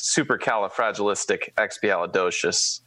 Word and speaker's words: supercalifragilisticexpialidocious: So, but for the sake of correctness here, in case anybody on the podcast also hasn't watched Mary Poppins, it is supercalifragilisticexpialidocious. – Very well. – That is how you pronounce the word.